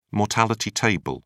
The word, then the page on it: mortality table: British English